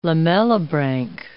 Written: lamellibranch

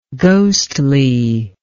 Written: ghostliness

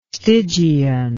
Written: stygian

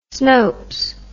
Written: snopes

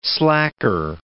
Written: slacker

